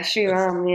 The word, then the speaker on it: Sriram: Now, Aasha has a cool surname that I can't pronounce, so I'm going to guess Sriram. Is that how I say it? – Yeah, Sriram, yeah. – Close enough, yeah.